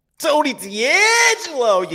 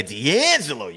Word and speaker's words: DeAngelo: Tony DeAngelo. You got to say it like that, Nate. All right. How many times I got to tell you, DeAngelo?